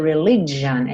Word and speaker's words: religion: Esse G do religion é J, como se fosse um DJ, tá? J.